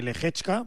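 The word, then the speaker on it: Lehečka: A las 11 de la mañana, hora española, la final de Tokio ante Lehechka.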